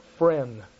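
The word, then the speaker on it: Fresnes: But we drove quite some while, and we ended up at a prison which they pronounce Friend.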